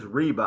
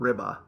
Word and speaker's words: Reba: The correct pronunciation of director Dan Reba's last name is Reba. I was saying Ribba, like rib and then which is how it's spelled.